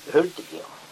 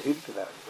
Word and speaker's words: Hultiger: Ja, Hultberg uttalar vi Hultiger. – Jaha. – Inte Hultberg. Hultiger. Hultiger.